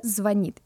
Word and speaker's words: звонит: А, я напоминаю нашим слушателям, которые, я уверена, абсолютно знают это правило наизусть, что ударение падает на последний слог. – Да. Он только звонит, и он обязательно вам позвонит.